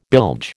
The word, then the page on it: bilge: American English